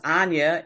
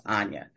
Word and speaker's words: Auaja: And my name is pronounced Anya, even though it doesn't look like that. It's a little long story, but I won't go into that for the sake of time, but it is spelled correctly, A-U-A-J-A, and it does sound like it has an N in it, so it's pronounced Anya.